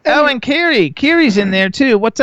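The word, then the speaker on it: Kerrie: But it's Carrie, but he has this weird Florida orange accent.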